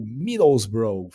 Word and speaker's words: Middlesbrough: ganhou, superou os 7,5 milhões que o Middlesbrough, falei certo, Vini? Falou. – Ah, Inglês perfeito.